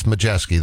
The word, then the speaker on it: Majewski: If you look at his name, you're not going to know how to pronounce Majewski. It's Modrzewski, but it's Majewski. That's how you pronounce his last name.